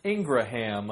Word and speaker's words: Ingraham: So, like, I always pronounced it Ingraham. – Well, it's like Graham, like Yeah, Graham. It's like that Graham with, like, an extra A in there.